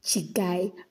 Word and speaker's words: Chiguy: Anyways, so today we are going to be discussing Chigai, right? Which is spelled C-H-I-G-U-Y, just quite literally Chigai.